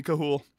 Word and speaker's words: Chuul: So the Chuul, I'll call it Chuul. That's how D&D pronounces it. We'll go for it. I'm just, that's my stance. I think it should be Kahul.